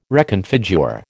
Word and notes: reconfigure: American English

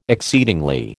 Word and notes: exceedingly: American English

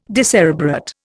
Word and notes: decerebrate: Indian

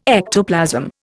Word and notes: ectoplasm: Indian

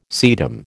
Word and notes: sedum: American English

